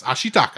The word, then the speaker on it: Ashitaka: So when I'm going to butcher his name, Ashitaka. – Yes. Ashitaka.